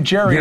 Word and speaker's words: Gerry: G-E-R-R-Y. – Gerry. Gerry. And they pronounce it Gerry, and they're not supposed to.